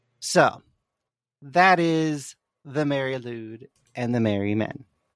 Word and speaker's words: Lwyd: And lewd, which is spelled L-W-Y-D, translates to white.